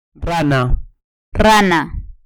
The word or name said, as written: rana